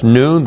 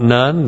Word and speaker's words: nun: That's noon. That's not none. That's noon.